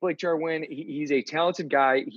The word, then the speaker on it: Jarwin: Blake Jarwin, the Dallas Cowboys open emphasis on the win. Am I right? – That's why I did that part.